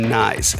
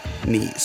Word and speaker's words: Nies: And just just right off the bat, I'd just like to mention last time um, I mispronounced his name Nyes, and I, I feel kind of silly about it because I've known him a really long time, but here I am mispronouncing his last name. So I felt a little silly, so it's actually pronounced knees.